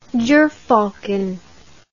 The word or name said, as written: jerfalcon